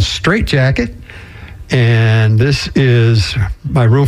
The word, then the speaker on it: Jacquet: So we've got a tune called Straight Jacket, and the jacket is spelled J-A-C-Q-U-E-T after the famous saxophone player Illinois Jacket.